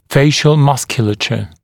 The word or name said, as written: facial musculature